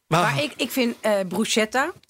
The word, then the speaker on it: bruschetta: Is, het is bruschetta. CH is altijd, altijd. uitgesproken als een K.